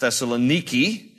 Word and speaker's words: Thessaloniki: It is pronounced Thessaloniki by the Greeks, and it is right on the Aegean Sea.